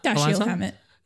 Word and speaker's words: Dashiell Hammett: Dashiell Hammett.